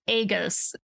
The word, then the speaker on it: aegis: It's A-E-G-I-S. And I think I thought it was aegis or aegis, and it's aegis.